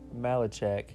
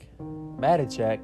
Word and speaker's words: Matichak: Andy Malachak. Matichak.